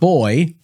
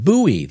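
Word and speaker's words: Buoy: Not boy, but Bowie. That's how you pronounce it.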